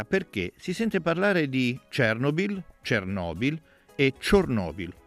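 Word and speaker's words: Chornobyl: in ucraino il suo nome è leggermente diverso, Chernobyl.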